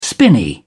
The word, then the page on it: spinney: British English